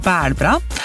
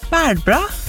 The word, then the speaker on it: bælbra: Bælbra. Bælbra. Bælbra. – Det klarer ikke jeg å uttale. Ta det rolig. – Bælbra. – Bælbra. – B-A-L, og så bra. – B-A-L. – Nei, B-A.